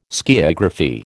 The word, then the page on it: skiagraphy: American English